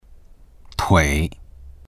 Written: tui3

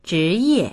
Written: zhi2ye4

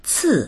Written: ci4

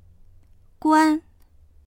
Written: guan1

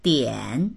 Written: dian3

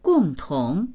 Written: gong4tong2

